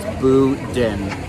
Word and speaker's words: Boudin: It says online the phonetic pronunciation is Boudin.